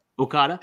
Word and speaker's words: Okada: But they tell such a amazing story in a contest, and that, you know, Akita, how do you say that? – Okada.